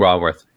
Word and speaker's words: Raworth: So I'll be honest, I did not know, Kate, is it Raworth? Is that how you pronounce her last name? – Raworth, yeah.